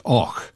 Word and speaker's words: Avoch: It's spelled A-V-O-C-H, but pronounced Och, in the Black Isle in the north of Scotland.